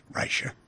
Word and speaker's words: Reicher: There's a psychologist, Stephen Reicher, pronounced Reicher, who studies group behavior at the University of St. Andrews.